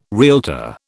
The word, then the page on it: realtor: British English